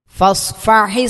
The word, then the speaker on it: فاصفح: ഫസ്ഫഹി സ്വഫ്ഹൽ ജമീൽ, ഫസ്ഫഹ്. – ഫ സ്വാദ് പറയുമ്പോൾ സീനാവരുത്, രണ്ടും അക്ഷരങ്ങൾ സെയിമായിട്ട് ഉച്ചാരണം വരുന്നത് ശ്രദ്ധിക്കുക. രണ്ടും സ്വാദ് തന്നെയാണ്, സീനാവരുത്.